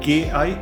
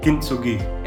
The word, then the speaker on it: Kintsugi: Kintsugi, if I'm not mistaken. – Kintsugi.